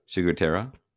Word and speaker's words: ciguatera: So the next one that I can never pronounce. – Oh, let's hear it. – Ciguatera. – Oh, that's pretty close.